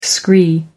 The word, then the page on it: scree: American English